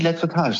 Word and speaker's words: Lettretage: Tom, habe ich das überhaupt richtig ausgesprochen? – Die Lettretage, sehr gut.